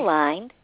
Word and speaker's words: Aligned: Is the owner of, is it Aligned or Aligned? How do you say it? Aligned. – Aligned, okay, because I know you emphasize the ED at the end for education, I think.